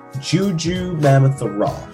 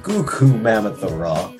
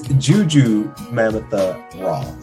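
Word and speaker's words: Gugu Mbatha-Raw: It's Juju Mamatha Raw. That is her name. Some people keep saying Goku Mamatha Raw. It's Juju Mamatha Raw.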